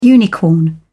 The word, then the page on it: unicorn: British English